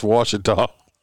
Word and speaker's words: Ouachita: No, it's pronounced Ouachita. – No, my ass. That thing says Ouachita. – No, it's not, but it's pronounced Ouachita. I'm telling you. It's not Ouachita or whatever.